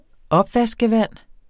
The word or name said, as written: opvaskevand